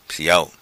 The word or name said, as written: Siao